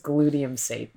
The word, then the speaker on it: glutamate: It's pronounced glutamate.